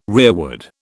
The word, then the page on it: rearward: British English